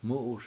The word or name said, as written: mU7uS